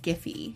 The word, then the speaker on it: Giphy: Is it Jiffy? Ooh, how do you pronounce it? – I asked them, I met people that work there, and I asked them, and they said Giphy.